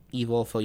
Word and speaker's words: Evolfo: Evolfo. – Evolfo. You want to spell that for our listeners? – Yep, that is E-V-O-L-F-O.